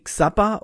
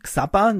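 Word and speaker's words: Xabber: Ich habe jahrelang zum Beispiel Xabba mit OTR genommen. Xabba, das habe ich nicht falsch ausgesprochen, das ist ein Client, der basiert auf Jabba OTR bzw.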